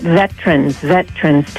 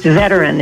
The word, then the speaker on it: veteran: He's mispronouncing veteran. He's calling it veterans. It's a three-syllable word. Can you hear me? – Hey, you sound great. – Yeah, and I wanted him to be corrected on that. It's veteran. It's three syllables.